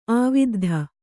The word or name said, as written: ಆವಿದ್ಧ